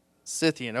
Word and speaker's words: Scythian: How do you say that word? Scythian.